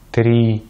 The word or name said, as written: три